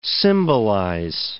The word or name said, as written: symbolize